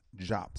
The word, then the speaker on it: Diop: And remember, family, even though it's, you see the word is D-I-O-P and some pronounce it D-O-P, it was pronounced Jop, so I'll try my best to pronounce it the way they want it pronounced.